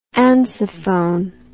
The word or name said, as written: ansafone